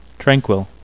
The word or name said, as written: tranquil